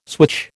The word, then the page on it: switch: American English